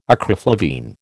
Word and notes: acriflavine: American English

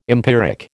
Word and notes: empiric: American English

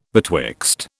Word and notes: betwixt: British English

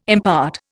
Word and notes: impart: Indian

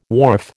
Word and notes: wharf: American English

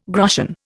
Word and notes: groschen: Indian

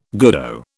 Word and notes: goodo: British English

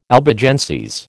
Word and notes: Albigenses: American English

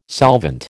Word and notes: solvent: American English

